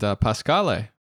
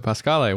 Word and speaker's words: Pascale: And Pascale, I hope I'm saying your name correctly also. Pascale, welcome. – It's Pascal, but Pascal. It was close.